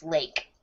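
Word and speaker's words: Lache: Lachey's Seastrunk's first name is pronounced Lake.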